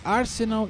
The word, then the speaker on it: Arsenal: Arsenal e Barcelona. É Arsenal? Falei certo? Falei, né? Falou, falou certíssimo.